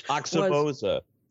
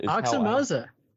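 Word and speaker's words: Aoxomoxoa: And that was the Grateful Dead's China Cat Sunflower from that unpronounceable Axiomoxa, whatever album, which. – Oxymoza. Uh, uh, say it again. Oxymoza. – Oxymoza. How I, how I was raised to pronounce it. I, I think that's right.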